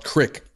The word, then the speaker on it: creek: And then I realized the word creek is pronounced crick up there.